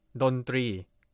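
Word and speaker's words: dondri (ดนตรี): Say it together with me, dondri. Dondri is a Thai noun, consists of two syllables. The syllable dri needs to be pronounced similar to the R sound in English.Another fun fact is that the word three actually means try or number three in Thai.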